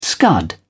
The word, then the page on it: scud: British English